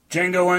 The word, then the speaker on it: Django: Django Unchained. The D is silent.